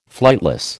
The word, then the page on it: flightless: American English